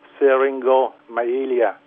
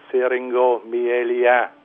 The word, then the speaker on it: syringomyelia: Thì bệnh này tên Anh ngữ thì gọi là bệnh syringomyelia. Thì nếu mà chúng ta đọc theo tiếng Việt thì chúng ta có thể đọc là syringomyelia, tiếng Pháp là syringomyelia.